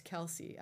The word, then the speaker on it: Kelce: In fact, I think this show has quite literally interrupted cover-to-cover coverage of Taylor Swift and Travis Kelce. I don't even know if I'm saying his name right. I think that's correct. – That's right, yeah.